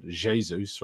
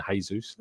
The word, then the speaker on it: Jesus: It's a hard J, I'm told. Jesus rather than Jesus.